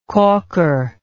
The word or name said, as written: calker